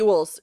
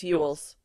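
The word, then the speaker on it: Elwes: Yules. It's Yules.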